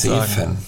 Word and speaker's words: Zeven: Der heißt, wird geschrieben Z-E-V-E-N.